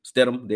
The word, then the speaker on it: Stidham: As you see here, even though Statham, or I don't know how to say his name either, Stidham. That quarterback from Stidham, there you go, isn't a running quarterback.